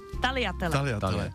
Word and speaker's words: Tagliatelle: Těstoviny, tagliatelle, nejsou to tagliatelle. – Víme, jak je to správně teda? – Tagliatelle. – Bez Gčka.